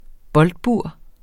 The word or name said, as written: boldbur